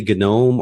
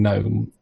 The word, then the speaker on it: GNOME: But my rationale for saying GNOME is that even though it's supposed to be a hard G because it stands for a hard G, what's the first G?